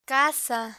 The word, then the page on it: caza: spanish